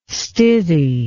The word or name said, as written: stithy